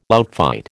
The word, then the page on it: outfight: American English